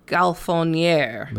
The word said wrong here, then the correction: Gonfaloniere: Gonfaloniere. I missed another fucking consonant syllable.